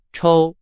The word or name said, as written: 抽